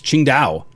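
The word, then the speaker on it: Tsingtao: It's pronounced Qingdao.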